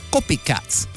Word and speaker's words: Kopikatz: But this one, they spell it like how I try to make it sound. The Copycats. You heard me say the Copycats, but why does it have to sound like that? Because it's spelled K-O-P-I-K-A-T-Z.